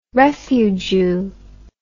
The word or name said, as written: refujew